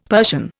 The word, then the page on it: Persian: Indian